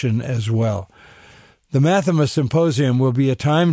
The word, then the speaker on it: Math3ma: And Mathema, again, is spelled M-A-T-H, the number 3, M-A.